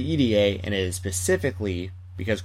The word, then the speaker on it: Irie: I rarely ever hear anyone use that pronunciation, but apparently from Shigehiro Irie's mouth himself, he told Cole Radrick it is pronounced E-D-A, like the letters E-D-A.